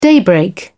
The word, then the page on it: daybreak: British English